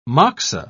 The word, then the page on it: moxa: British English